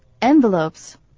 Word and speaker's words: envelopes: Envelope 这个字呢是要注意到信封的念法重音在第一个音节，这个字的拼法呢有点奇怪， ENVELOPE，英文的重音摆在前面念作 envelopes。